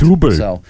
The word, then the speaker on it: Dubbel: It's spelled D-U-B-B-E-L. – Correct. – Double.